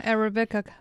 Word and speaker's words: Arabica: Prices hit a 40-year high back in 2024 for, how do you pronounce that, Arabica? – Yeah, Arabica beans.